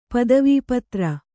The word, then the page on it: ಪದವೀಪತ್ರ: padavīpatra